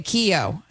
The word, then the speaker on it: Keyo: Okay, it's like a key with an O on it. – Okay, Keyo.